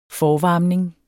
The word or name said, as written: forvarmning